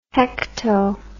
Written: hecto

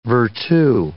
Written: virtu

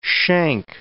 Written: shank

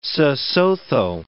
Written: Sesotho

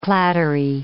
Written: clattery

